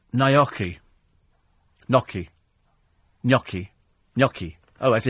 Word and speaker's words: gnocchi: Giulietta says, being half Italian, I feel it's my duty to advise you they're pronounced gnocchi, rhyming with hockey and a yummy.